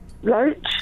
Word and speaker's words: Loach: Rhymes with Roach. – Ken Loach? – Yes.